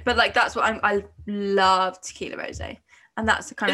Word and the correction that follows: Tequila Rose: Did I say that wrong? Is it tequila rose? – I'd say tequila rose.